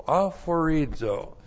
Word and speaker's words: aphorizo: A-P-H-O-R-I-Z, long O, aphorizo.